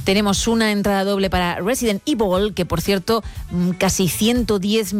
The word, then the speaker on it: Resident Evil: Creo que se están riendo los compañeros de que digo Resident Evil. Me da igual, es cómo se pronuncia. Es verdad que aquí hemos dicho siempre Resident Evil.